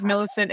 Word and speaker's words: Millicent: Yeah, my name is Millicent.